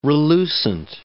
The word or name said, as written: relucent